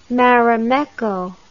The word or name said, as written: Marimekko